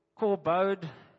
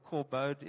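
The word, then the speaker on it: kobod (כבוד): The Strong's definition says the Hebrew word is kobod. It's obviously pronounced like that, but it's spelled kabod, or just K-A-B-O-D with funny symbols. So you say it sort of kobod in the Hebrew.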